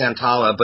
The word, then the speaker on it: Santolla: It's Santolla, but I know you swamp folks can barely read anyway, so that's no problem.